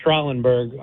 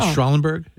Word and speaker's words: Schrallenberg: It's pronounced Schrallenberg. I'm from a town called Dumont that used to be called Schrallenberg. – Okay, so we know. So we were correct, EJ. – I said it correctly. Is it Schrallenberg? – Yeah.